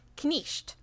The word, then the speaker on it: knight: In Middle English, which Chaucer spoke, that was pronounced knisht. So you pronounce the K and you pronounce every other letter.